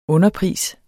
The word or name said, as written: underpris